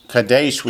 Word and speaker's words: Kadesh: Kadesh we usually pronounce as Kadesh in English, but we anglicize everything. Kadesh is Kadesh.